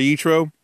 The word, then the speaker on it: Yitro: Pun intended, because it's Parsha Yitro, which rhymes with nitro.